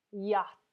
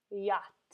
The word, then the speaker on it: yacht: Yacht. Yacht. This looks very different than the way it is pronounced.